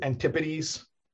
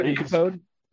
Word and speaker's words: Antipodes: A-N-T-I-P-O-D-E-S. Antipodes. – Oh, it's not antipode? It's Antipodes? – No, Antipodes. Like antithesis, not antithesis.